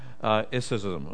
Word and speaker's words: Gnosticism: And we would spell that word G-N-O-S-T, Gnosticism, okay? Gnosticism.